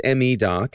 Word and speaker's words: MeDoc: I believe it's referred to as MeDoc is the correct way to say it.